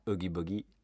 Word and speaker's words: Oogie Boogie: Oogie Boogie.